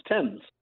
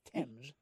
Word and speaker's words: Thames: Fun fact, the river spelled T-H-A-M-E-S is pronounced Thames. – Not Thames.